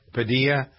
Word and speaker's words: Padilla: Alex Padilla, is that how we pronounce his name? – Padilla.